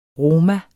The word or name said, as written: roma